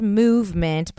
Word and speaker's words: MVMT: MVMT watches, so it's pronounced movement, but just MVMT because that's what's on like the website URL thing.